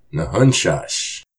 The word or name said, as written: Nahunshásh!